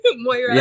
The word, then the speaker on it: Moira: Moira.